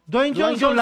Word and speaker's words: Dwayne Johnson: Dwayne Johnson, La Roca.